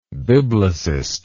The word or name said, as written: biblicistic